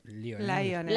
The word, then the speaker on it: Lionel: Jeffrey Lionel Dahmer nació el 21 de mayo de 1960 en West Allis, Wisconsin, hijo de Joyce Flint y Lionel, Lionel, ¿cómo se dice? – Lionel. Lionel, ahí está.